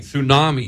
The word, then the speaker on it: tsunami: With the silent T and everything. Tsunami.